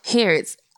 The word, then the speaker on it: Harrods: Harrods.